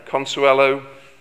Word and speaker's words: Consuelo: He married a wealthy American by the name of Consuelo. I'm not sure if I've got that pronunciation right. Can you correct me? Yeah, Consuelo Vanderbilt.